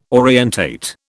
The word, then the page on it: orientate: British English